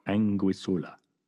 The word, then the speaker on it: Anguissola: Because I just saw a show in Madrid of, I can't pronounce her last name, Sophonisba Anguissola.